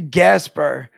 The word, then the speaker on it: Gaspare: So it's actually Gaspare. – Oh, Gaspare. Like, I'm sorry. – No, it's everybody's butchered it my whole entire life. It's like Casper the Friendly Ghost with a G, but I've been called like every variation of that, those letters.